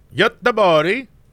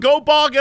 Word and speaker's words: Göteborg: It is pronounced in Swedish, Göteborg. – Göteborg. – Göteborg. – Göteborg. – Göteborg. That's what they're saying. That's how it's pronounced. – Göteborg. – It's not goat. It's not goat bog at all.